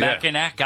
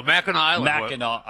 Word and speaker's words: Mackinac: When he goes to Mackinac Island? – Oh, no, Mackinac Island.